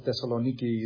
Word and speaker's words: Thessaloniki: Colossae, Thessalonica, or Thessaloniki is the correct pronunciation, Philippi, and so forth.